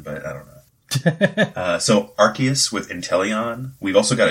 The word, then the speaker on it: Arceus: And then the other decks we've got, we have an Arceus, and they came out with the correct pronunciation recently, didn't they? Like the official one? Is it Arceus? – I think it's with a hard C, yeah. – Yeah, Arceus.